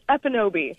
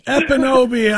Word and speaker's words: Ekpunobi: I'm guessing it's Ekpunobi. Am I saying the last name correctly? – The K happens to be silent, so it's Ekpunobi. – Ekpunobi, I'm such an idiot.